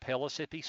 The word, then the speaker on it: Pellissippi: It's just a one-night, two-hour class at Pellissippi. I'm sorry, I may have said that wrong. It's at Pellissippi State Community College in Hardin Valley, August the 30th.